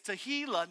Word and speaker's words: tehillah: I heard a guy, a preacher named Robert Morris, say it's tehillah, not tequila.